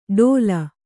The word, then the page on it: ಟೋಲ್: Kannada